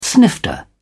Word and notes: snifter: British English

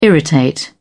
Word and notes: irritate: British English

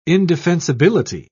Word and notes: indefensibility: British English

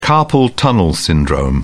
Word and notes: carpal tunnel syndrome: British English

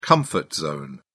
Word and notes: comfort zone: British English

